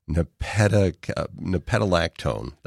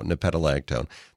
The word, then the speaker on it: nepetalactone: And it contains a chemical called nepetic, I got to pronounce this correctly, nepetalactone. There you go, nepetalactone.